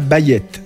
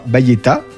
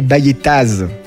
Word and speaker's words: Bayettaz: Je parlerai de la Bayette sans prononcer le AZ final. Vous entendrez parfois Bayetta avec le A, mais jamais Bayettaz avec le Z. Prononcer ça, c'est un sacrilège. Le Z est presque toujours muet.